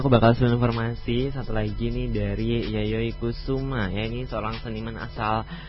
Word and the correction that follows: Kusama: Oh, I'm sorry, I miss the pronunciation. It has to be Yayoi Kusama, not Kusuma.